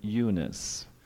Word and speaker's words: Eunice: we pronounce it Eunice, and I am convinced that it is in you as well. But she has a name, she has a Greek name, Eunike,